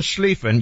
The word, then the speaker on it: Schlieffen: The Von Schlieffen Plan was a German military strategist, Alfred von Schlieffen. You have to say his name like that.